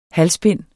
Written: HALSBINDENES

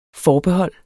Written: FORBEHOLDENES

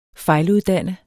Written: fejluddanne

